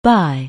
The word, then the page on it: buy: anglais